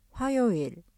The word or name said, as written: 화요일